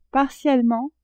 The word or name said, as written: partiellement